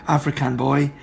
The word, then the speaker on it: Afrikan Boy: It's spelt A-F-R-I-K-A-N. So, African Boy.